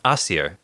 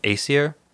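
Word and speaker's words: Æsir: Asir. – Asir. – You might be more familiar with the pronunciation Aesir or Azir.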